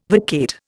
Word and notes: vacate: Indian

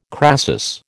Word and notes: crasis: American English

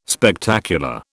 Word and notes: Spectacular: British English

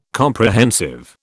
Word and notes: Comprehensive: British English

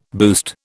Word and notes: boost: British English

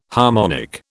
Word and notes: harmonic: British English

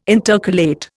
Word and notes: intercalate: Indian

